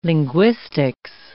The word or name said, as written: linguistics